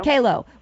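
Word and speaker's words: QALO: whether that be Advance or how do you pronounce it? Coelho or Kalo? – Kalo with the rings that you guys have.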